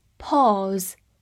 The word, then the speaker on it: pause: pause，p a u s e，pause，这里的 a u 字母组合发 o 这个长音，末尾的 s 发 z 浊辅音，最后的 e 不发音。 Pause，好，这个单词呢有两个词性，第一个是一个名词，表示暂停或者是间歇。